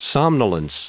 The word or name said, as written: somnolence